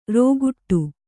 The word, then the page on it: ರೋಗುಟ್ಟು: rōguṭṭu